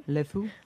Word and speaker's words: LeFou: LeFou. – Yes. – Is that even how you say it? – LeFou.